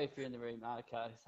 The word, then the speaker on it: Arnica: I'm not even saying it right. It's the cream. Arnica. – It's Arnica. Yeah. Arnica cream. – It is Arnica cream, yeah.